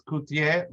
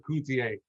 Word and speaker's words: Cutié: It's Cutié, but don't worry, in English we have no accents, so Cutié's just fine. Or Cutié, yeah.